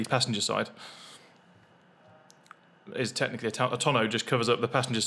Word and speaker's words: tonneau: I never know how to pronounce this word. Is it a tonneau roof? A tonneau. Tonneau? – Yeah, it's got a tonneau.